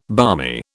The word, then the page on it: barmy: British English